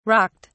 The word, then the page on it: rocked: British English